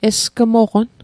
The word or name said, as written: esgmoqon